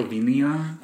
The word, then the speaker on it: Herzegovina: I can never say that right. – Herzegovina? – Thank you. Thank you.